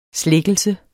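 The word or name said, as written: slækkelse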